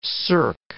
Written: cirque

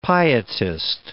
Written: pietist

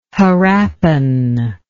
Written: harappan

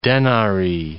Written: denari

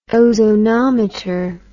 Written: ozonometer

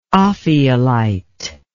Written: ophiolite

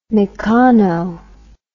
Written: meccano

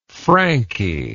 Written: Franky

